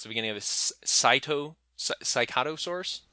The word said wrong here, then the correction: Psittacosaurus: I've been bouncing back and forth. I go right? – Psittacosaurus. – Psittacosaurus. – Yeah. – We'll go with that.